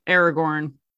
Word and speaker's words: Aragorn: Aragorn.